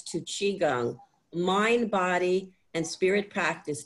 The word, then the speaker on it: Qigong: So we're gonna do about 45 minutes together of an exercise called Qigong. It's pronounced Qi as in C-H-E-E, Gung, G-U-N-G. That's how I would write it phonetically. And the way we write it in English is Q-I-G-O-N-G.